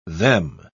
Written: them

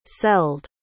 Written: celled

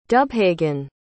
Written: dubhagain

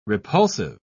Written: repulsive

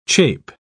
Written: chape

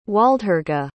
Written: Waldhurga

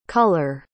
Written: culler

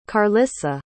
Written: carlisa

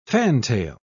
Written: fantail

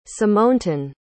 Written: symontun

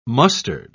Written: mustard

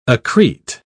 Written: accrete

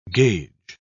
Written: gauge

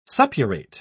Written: suppurate

